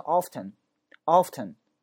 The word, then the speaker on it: often: 它的发音两种，你可以说成 often, 也会把这个单词发成 often, often, 这个 T-E-N 直接读成 ten, often, OK, 这个 T 可发音可不发音，很多美国人都会把这个读成 often, OK, 说我经常去图书馆，那怎么讲？